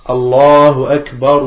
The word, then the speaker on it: Allahu Akbar: Non. Allahu Akbar, Allahu Akbar.